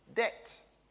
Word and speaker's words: debt: Debt. Okay. There is debitum, there is a B in there, therefore there must be a B, even though it is nowadays a silent B, even though it was probably back then, it was never a debtor or something like that.